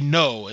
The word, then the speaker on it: Gno: His name is DJ No, and that's spelled G-N-O.